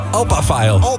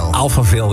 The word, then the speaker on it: Alphaville: ja, hoe spreek je dit uit? – Opa-file. – Alphaville, ja, wat je wil.